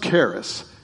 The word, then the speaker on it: charis: The Greek word translated grace is, and it's pronounced charis. It's C-H-A-R-I-S, but it's pronounced like with a K.